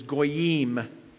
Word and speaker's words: goyim: The Old Testament word for a heathen person or nation in Hebrew, pronounced in the English, is goyim, G-O-Y-I-M.